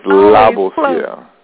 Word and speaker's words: Labossiere: It was close. It was very close. It's Labossiere. – Labossiere.